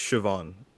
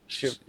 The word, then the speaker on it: Siobhan: Now, I'm not sure if I'm pronouncing her name right, but is it Siobhan? – I think it's Siobhan. – Siobhan. – Siobhan Reddy. Okay.